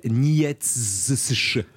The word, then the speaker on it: Nietzsche: Ça s'appelle Nietzsche.